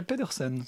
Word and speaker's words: Pedersen: Et ils se sont livrés un match d'anthologie euh, que le réalisateur suédois Janus Metz euh, pédestre. Je suis pas très sûr de la prononciation. Moi j'ai Pedersen.